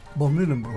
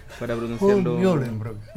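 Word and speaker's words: von Mühlenbrock: Von Mühlenburg. – Von Mühlenburg. Que usted ¿sí? lo pronuncia muy bien, yo lo tengo que leer para pronunciarlo. – Von Mühlenburg.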